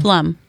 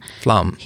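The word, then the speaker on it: Flom: Flum. Jason Flum. – Flum.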